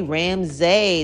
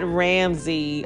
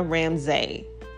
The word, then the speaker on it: Ramsay: That's R-A-M-S-A-Y. You know what? Let me just say it right now. My name is not Ramsey. Okay, even though I say it, even though a lot of other people say it, we say it because trying to correct people seems like it'd be like, Lord, they're gonna never get this name right. But it's really Ramsay, okay? It's Tina Ramsay. FYI.